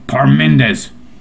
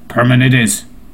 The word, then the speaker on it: Parmenides: It's spelled like Parmenides, so it's gotta be Parmenides. Parmenides.